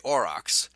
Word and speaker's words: aurochs: It looks as though it is later than the aurochs. And probably ox, probably where we get the word ox from. It's spelled A-U-R-O-C-H-S, auroch.